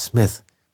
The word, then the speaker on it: Smith: Mr. Smith konnte dann einer sagen, aber der nächste meinte Mr. Smith. Ja, es heißt nicht Smith, es heißt Smith.